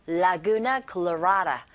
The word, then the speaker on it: Laguna Colorada: 발음하시면 되고 la, la, Laguna Colorada 라고 발음하시면 됩니다.